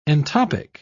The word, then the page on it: entoptic: British English